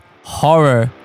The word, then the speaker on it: Ho99o9: Well, that was horror, and that is spelled ho nine nine oh nine. Yes, horror, but with three nines.